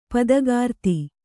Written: ಪದಗಾರ್ತಿ

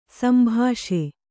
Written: ಸಂಭಾಷೆ

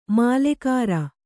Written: ಮಾಲಗಾರ

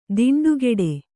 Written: ದಿಂಡುಗೆಡೆ

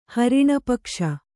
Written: ಹರಿಣಪಕ್ಷ

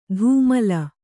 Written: ಧೂಮಲ

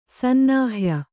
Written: ಸನ್ನಾಹ್ಯ

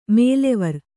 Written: ಮೇಲೆವರ್

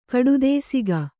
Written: ಕಡುದೇಸಿಗ

